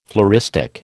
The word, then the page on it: floristic: American English